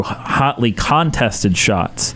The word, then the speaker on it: hotly contested: They can't do it through through hotly contested shots. I said that weird. Hotly contested shots. – There you go. – Weird. – Emphasis on the wrong syllable.